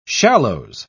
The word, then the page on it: shallows: British English